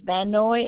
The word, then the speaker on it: Van Noy: Last name is Van Noy.